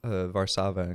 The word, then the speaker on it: Warszawa: Oh yeah, which are pronounced Warszawa and Krakow, but.